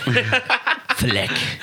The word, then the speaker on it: Fleck: Fleck. F-L-E-C-K.